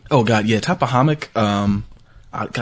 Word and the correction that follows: Tappahannock: Tappahannock. You said I was saying it wrong. I kept saying the M. It is Tappahannock, Virginia, and 2,000 people.